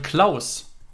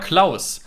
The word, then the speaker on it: Clauss: Und zwar geht es da um äh, Jonathan oder Jonathan, wenn er jetzt äh, französisch ausgesprochen wird, Klaus. Jonathan Klaus. Mhm. C-L-A-U-S-S.